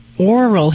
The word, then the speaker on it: Aural: I have a 6-year-old English male bulldog who has a, I think it's pronounced a Aural Hematoma, also known as pillow ear. Are you familiar with that problem? – I sure am. And just for those that maybe can't hear real clearly, Aural Hematoma, it's spelled A-U-R-A-L, and it basically means swelling of the ear.